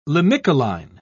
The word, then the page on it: limicoline: British English